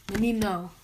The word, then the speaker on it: menino: Menino. Menino, not menino. – Man. – The thing about Portuguese is, it's your turn. – It's like O and A are different syllables. So it's like, I can say menina, and that means girl. Menino means...